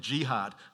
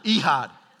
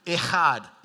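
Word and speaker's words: Ihad (אחד): Remember last week I talked about the word one. Ihad. Someone thought I said jihad. Ihad. Ehad. E-C-H-A-D, we would spell it.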